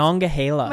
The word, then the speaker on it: Monongahela: Monongahela.